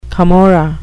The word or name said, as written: camorra